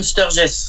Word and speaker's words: Sturges: Les sept mercenaires, alors une musique qui est signée Elmer Bernstein, pour un film réalisé par John, alors j'espère que j'ai bien prononcé, John Sturges, je ne sais pas si Sturges. – John Sturges. – Sturges, j'avais un doute sur le S.